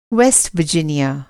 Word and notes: वेस्ट वर्जिनिया: Hindi

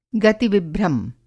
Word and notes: गति-विभ्रम: Hindi

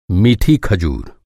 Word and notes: खजूर: खजुर

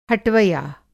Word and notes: हटवया: Hindi

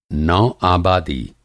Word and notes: नौ-आबादी: Hindi

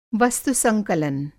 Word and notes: वस्तु-संकलन: Hindi

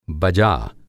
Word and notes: बजा: Hindi

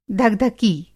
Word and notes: धकधकी: धक-धकी

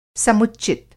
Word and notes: समुच्चित: Hindi

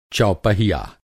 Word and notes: चौपहिया: Hindi

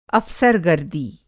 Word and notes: अफ़सरगर्दी: Hindi

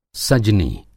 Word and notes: सजनी: سجنی

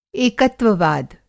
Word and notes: एकत्ववाद: Hindi

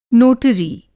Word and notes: नोटरी: Hindi

